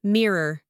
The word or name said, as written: mirror